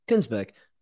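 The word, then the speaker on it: Kuenssberg: Let's just have a little clip from Steve Barclay, who was on Laura Kunersberg's. How do you pronounce her name? I've never really got it wrong. – Kunersberg.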